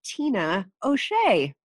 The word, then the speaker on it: Tina O'Shea: Today, I have Tina O'Shea. Did I say that right? – You did.